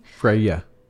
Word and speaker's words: Freya: Freva Stark, is that how you say her name? – Freya. – Freya.